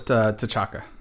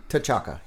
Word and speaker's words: T'Chaka: The father's T'Chaka. – T'Chaka, yeah.